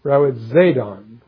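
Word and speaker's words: rawitzadon (ῥοιζηδόν): You pronounce it rawitzadon, rawitzadon. And it is thought to be sort of the sound of a whizzing arrow as it goes by.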